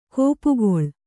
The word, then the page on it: ಗುಪ್ಪೆಗೊಳ್: guppegoḷ